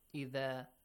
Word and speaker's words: إِذَا: وَإِذَا قِيلَ لَهُمْ إِذَا pronunciation of the letter of dhal. Say it once. – إِذَا إِذَا وَإِذَا إِذَا نُو إِذَا أَلِفْمَتْ قِيلَ لَهُمْ Qila is the passive form of qala.